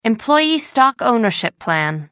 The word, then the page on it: employee stock ownership plan: American English